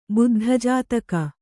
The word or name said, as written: ಬುದ್ಧಜಾತಕ